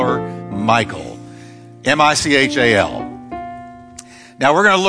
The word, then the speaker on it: Michal: Now, I debated over what to call her, because you can call her Michal, but if you want to go Hebrew, you can call her Michal or Michal.